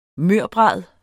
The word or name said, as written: mørbrad